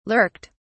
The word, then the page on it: lurked: British English